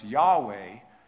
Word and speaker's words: Yahweh: what is pronounced oftentimes Yahweh, is translated as Lord. And the letters are capitals, all capitals, L-O-R-D, in your King James and New King James Bible.